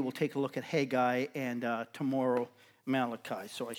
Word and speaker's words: Haggai: Some of you get a little extra syllable in there. That's the way I was taught. Haggai. We get three syllables in there, but often it's just Haggai. And Haggai's a prophet who is going to prophesy to them after they've come back from exile.